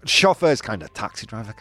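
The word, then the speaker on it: chófer: Yeah, with the stress on the O. Oh, chauffeur. – Chauffeur.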